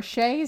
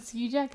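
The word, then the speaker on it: Skjei: Or, sorry, Shea is how you say his name, but I still pronounce it Ski Jet, because it's S-K-J-E-I.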